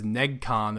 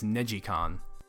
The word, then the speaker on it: NeGcon: I always pronounce the first one as NegCon. Apparently, it's NegiCon.